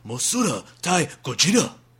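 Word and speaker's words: Mosura Tai Gojira (モスラ対ゴジラ): Or the Japanese title, Mosura Tai Gojira.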